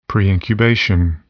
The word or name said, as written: preincubation